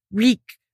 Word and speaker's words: Reek: Reek, Reek, it rhymes with sneak.